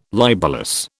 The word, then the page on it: libellous: British English